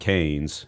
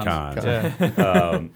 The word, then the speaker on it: Cannes: Went to, I first pronounced it Cannes, and then I figured out it wasn't Cannes. It's actually Cannes.